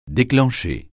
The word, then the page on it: déclencher: French